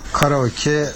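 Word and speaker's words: کاراکی (カラオケ): در مورد کاراکی باید بگم که تلفظ شما کاملا درسته. من به عنوان ایرانی که 26 ساله تو ژاپن زندگی میکنم، کاراکی همونی که شما میگین درسته.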